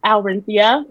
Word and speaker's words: Alrinthea: It's Aurinthia, and it's just my name, A-L-R-I-N-T-H-E-A.